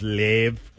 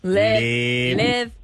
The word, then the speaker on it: Liev: It also could be pronounced Liev. – Liev.